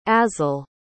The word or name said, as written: azel